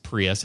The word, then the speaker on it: Prius: Nobody knew it was pronounced Prius at the time, hardly, you know.